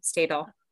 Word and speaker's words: Stadol: I mean, because so you attribute it to having been on the Stadol. That's how you pronounce it, right? Stadol. Yeah.